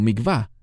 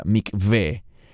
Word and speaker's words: Mikveh: Normalmente los mesiánicos que hablan inglés lo pronuncian como Mikvah. No es Mikvah, es la palabra Mikveh.